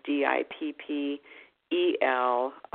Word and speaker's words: Dippel: Or they could go on my website, just dot com, And of course, my last name is D I P P E L. A lot of most of the time, people want to spell it L E, but it's D I P P E L dot com.